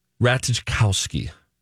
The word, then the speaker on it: Ratajkowski: Emily Ratajkowski. R-A-T-A-J. – Try it. – K-O-W-S-K-I. – Say it. – Emily Ratajkowski. – That's nice. Good job.